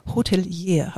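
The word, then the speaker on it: Hotelière: Und du bist Hotelier. Habe ich das jetzt richtig ausgesprochen? Korrekt, perfekt.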